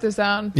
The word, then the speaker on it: DAZN: Or is it DAZN? Is that how you say it? DAZN. – They're both DAZN.